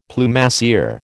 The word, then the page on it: Plumassier: American English